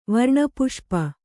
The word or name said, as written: ವರ್ಣಪುಷ್ಪ